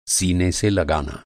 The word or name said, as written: सीना